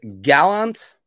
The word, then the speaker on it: Gallant: Yeah, I was told it's Gallant. That's the way he pronounces it, but he's also the office space.